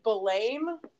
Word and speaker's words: Balaam: I think it's spelt like B-A-L-A-A-M, Blame. – I have always said that name, Balaam.